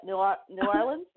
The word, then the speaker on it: New Orleans: Well, the other burning question I have is because I've never been to New Orleans, and I don't know how to pronounce it. Is that New Orleans? Is that, did I say it right? – You actually did it really, really well.